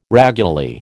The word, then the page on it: raguly: American English